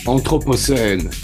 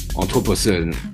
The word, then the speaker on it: Anthropocène: Anthropocène. Anthropocène. In French, yeah. Yeah, all the tracks of Circle Futur are written in French. It's just that I decided. And Anthropocène, I think it describes the period of the Earth where man has an influence on it.